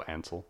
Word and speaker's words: Ansel: Ansel is the hard part. – I just think of Hansel and then I go Ansel.